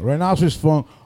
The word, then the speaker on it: Rhinoceros Funk: Rhinoceros Funk. R-H-I-N-O-C-E. R-O-S.